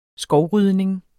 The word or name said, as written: skovrydning